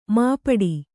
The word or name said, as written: ಮುಪ್ಪಟ್ಟೆ